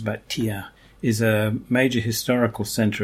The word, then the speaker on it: Thiers: It's manufactured in an interesting city in central France called Thiers. That's how you pronounce it.